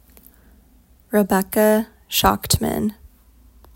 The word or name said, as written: Schachtman Rebecca